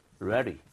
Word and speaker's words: ready: 자, READY 요거 발음할 때 우리 뒤에는 D 부분을 부드럽게 발음해 보실게요. 그래서 READY가 아니라 리을 비슷하게, 완벽하게는 아닙니다. 비슷하게, 리을 비슷한 거. 모음 사운드 사이에 D나 T가 들어가면 보통 리을 비슷해져요.